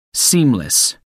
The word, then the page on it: seamless: American English